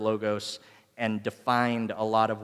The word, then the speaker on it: logos: So the word for word that John is using here is logos. It's actually pronounced logos, but that's annoying, and I'm not going to do that.